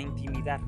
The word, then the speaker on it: intimidar: nos empezamos a intimar, perdón, a intimidar, lo pronuncié mal, nos empezamos a intimidar por los trabajos de los demás.